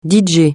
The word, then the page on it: DJ: francais